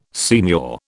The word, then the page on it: signor: British English